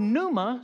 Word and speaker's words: pneuma: And we know from the New Testament perspective that the word that's used for breath is the word pneuma, spelled with a P-N, pneuma, and it also describes spirit.